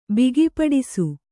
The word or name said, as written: ಬಿಗಿಪಡಿಸು